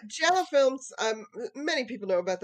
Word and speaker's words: Giallo: Giallo, see, there you go, pretty good.